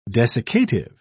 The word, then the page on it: desiccative: British English